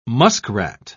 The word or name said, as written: muskrat